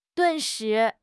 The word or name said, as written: 顿时